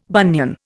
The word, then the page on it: bunion: Indian